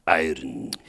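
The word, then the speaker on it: Irn-Bru: So it's spelled I-R-N hyphen B-R-U. – Iron.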